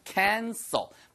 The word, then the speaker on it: cancel: c-a-n-c-e-r 그거는요. cancer 그거는 er 발음을 하시면은 암이란 뜻이고요. cancel ul 발음을 하시면은 취소하단 뜻이에요. 자, 취소하단 cancel. 자, 암은 cancer.